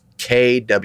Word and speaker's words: Quibi: It's the pronunciation when they sound it out is Kwihbee. So Quibi, and kind of like Kiwi, but Quibi, you know.